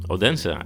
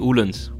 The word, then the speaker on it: Odense: Odense. Eigenlijk zeg je Oelens. Oelens? Oelens. Eigenlijk spreek je de D niet uit, dus heb je het geleerd.